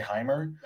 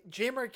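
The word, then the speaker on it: Jaimer: Jamer, I thank you for saying that because I was going to say Hymer. – I thought it was Hymer too until I listened to the highlights and then like Jamer came to life.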